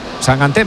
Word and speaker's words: sangante: Sangante, me ha dicho Sangante, se pronuncia sangante. – Yo pronuncio como me digáis. – Bueno, eso lo he oído yo, que dice que es sangante. – De hecho me ha dicho, tú acuérdate de mangante.